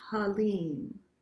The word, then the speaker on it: Halim: the divine name or the divine quality is Halim. Halim, H-A-L-I-M, but pronounced, if it's easier to write, H-A-L-E-E-M. And so the emphasis is on the lim, Halim.